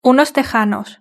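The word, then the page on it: unos tejanos: Spanish (Spain)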